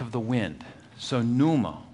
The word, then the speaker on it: pneuma: And this word Spirit is the Greek word, we would say it pneuma, but when you in Greek you pronounce the P just a little bit. So they would say pneuma.